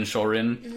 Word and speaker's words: Shorin: So his name actually is the Japanese pronunciation of Shaolin. So Shaolin Shorin.